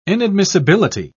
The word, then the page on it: inadmissibility: British English